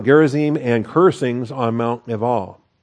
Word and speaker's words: Ebal: So Joshua built an altar to the Lord, the God of Israel, in Mount Ebal. Again, that's a terrible pronunciation. If you want to be a Hebrew pronouncing purist, do that. Leval.